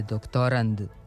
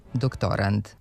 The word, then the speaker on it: doktorand: Kod tog tipa pozajemljenica, akcenat se s kraja reči pomera za jedno mesto unapred i od silaznog postaje uzlazni, tako da izgovor i oblik u skladu sa normom glasi doktorand.